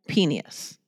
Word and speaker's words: Penius: It came across in my email, and it said Global Penius. I was like, spell that. – P-E-N-I-U-S.